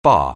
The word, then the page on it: bar: anglais